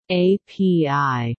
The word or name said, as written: API